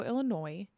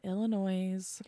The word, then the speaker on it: Illinois: So Ronald Gene Simmons was born July 15th, 1940 in Chicago, Illinois. Not Illinois. – Yeah. The S is silent.